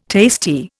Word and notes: tasty: Indian

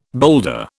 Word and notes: boulder: British English

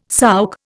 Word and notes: souk: Indian

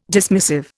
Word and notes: dismissive: Indian

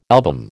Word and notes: album: American English

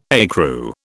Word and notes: ecru: British English

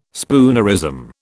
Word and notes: spoonerism: British English

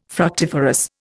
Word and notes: fructiferous: Indian

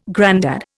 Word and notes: grandad: Indian

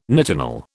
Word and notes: nitinol: British English

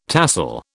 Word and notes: tahsil: British English

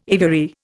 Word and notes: aviary: Indian

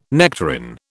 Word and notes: nectarine: British English

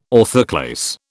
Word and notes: orthoclase: British English

